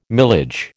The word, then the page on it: milage: American English